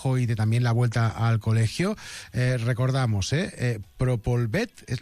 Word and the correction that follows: Propolvit: lo he dicho bien, Defense. – Propolvit.